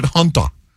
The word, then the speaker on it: hanta: They spell it H-A-N, but they pronounce it Hunter. So, you know, some countries around the world, they pronounce A's different than we do. The A can be pronounced as a U.